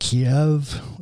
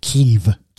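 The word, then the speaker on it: Kyiv: So it turned out that she has a friend who lives, we still call it Kiev. Ukrainians don't like that because that's the Russian pronunciation. They call it Kiev.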